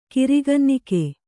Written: ಕಿರಿಗನ್ನಿಕೆ

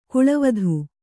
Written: ಕಳಾವತಿ

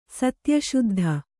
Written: ಸತ್ಯಶುದ್ಧ

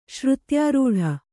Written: ಶ್ರುತ್ಯಾರೂಢ

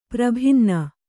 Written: ಪರಿಭಿನ್ನ

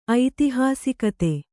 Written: ಐತಿಹಾಸಿಕತೆ

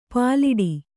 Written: ಪ್ಲೇಟು

